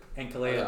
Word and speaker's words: Ankalaev: What's how do you say it? – Ankalaev. – I would take Ankalaev. – Honestly, I'm not even sure if I'm saying it right, but I'm just going off.